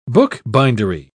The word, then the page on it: bookbindery: British English